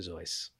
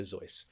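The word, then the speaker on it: Seuss: And interestingly, I'm a huge fan of Dr. Zeuss. – Right. – A lot of people mispronounce it as Dr. Zeus, but it's actually Dr. Zeuss.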